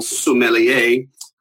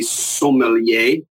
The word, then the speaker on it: sommelier: Look, when it comes to pronunciations, the English would say every single syllable sommelier. The French just kind of lazily, lackadaisically say sommelier. And in the U.S., you know, we are a very young country when it comes to food and wine. So we say sommelier, sommelier, and it's just a lot of words that kind of come out on the American side.